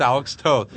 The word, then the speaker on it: Toth: No, it's Alex Toth.